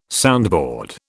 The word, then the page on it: soundboard: British English